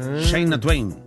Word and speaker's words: Shania Twain: Algunas le llaman Shania Twain. Shania Twain. Más correcta la pronunciación.